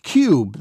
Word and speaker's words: QUBE: It aired on a local cable system in the Columbus, Ohio area called Cube, that's spelled Q-U-B-E.